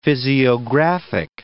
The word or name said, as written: physiographic